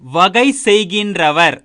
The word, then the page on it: வகைசெய்கின்றவர்: Tamil